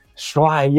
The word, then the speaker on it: Shwai: Shwai, yes, you got it. – Man, I felt terrible. Like, I was calling you Shui for a while.